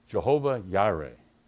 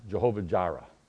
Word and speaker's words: Jehovah Jireh: And so when you look at the scripture in the original text, it refers to God this way, Jehovah Yireh. We pronounce it Jehovah Jireh, okay, which means God will provide.